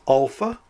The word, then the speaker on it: alpha: The first letter is Alpha, pronounced as A as in Father, or A as in Bat, A.